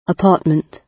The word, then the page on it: apartment: British English